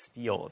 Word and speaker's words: stele: I didn't know how to pronounce that last word. I was like, is it stele? Like, I think it's stele is the way you pronounce it.